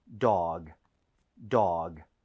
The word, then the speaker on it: dog: 자, 그리고 발음할 때 너무 duck 하고 끝내지 마세요. 이거는 네. – dog이기 때문에 g로 끝납니다. 부드럽게 하셔야 돼요. – 네. – 자, 한번 해볼게요. dog, dog.